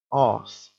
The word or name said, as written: arse